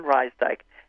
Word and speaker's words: Rysdyk: Evelyn Rysdyk.